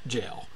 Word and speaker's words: gaol: And by the way, G-A-O-L is pronounced jail. That's the old spelling of that word.